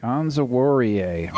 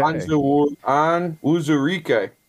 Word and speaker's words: Onwuzurike: How's that pronounced again? – Onwuzurike. – Okay. – Onwuzurike.